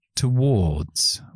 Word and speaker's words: towards: Ya no es out woods, for woods, up woods, sino towards. Usamos la O larga, la O que es como una C al revés con dos puntos. Towards.